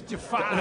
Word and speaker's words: Jafar: And that makes me like Jafar. – Yeah. – I didn't mean to say it like Christopher Walken, but... – It's Jafar.